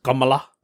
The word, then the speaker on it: Kamala: And her name is Kamala Harris, but obviously because she's Jamaican Indian, her first name is Indian, so it's actually pronounced Kamala.